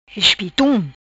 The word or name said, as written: Espitun